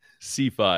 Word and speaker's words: CeFi: Okay, how does the team pronounce it? Is it CeFi? – CeFi.